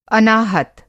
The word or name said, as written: अनाहत